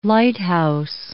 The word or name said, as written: lighthouse